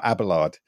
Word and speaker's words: Abelard: I got a chance to do a poem about Abelard. I don't even know if I'm saying that right, Eleanor. But, yeah, that's right.